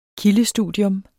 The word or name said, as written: kildestudium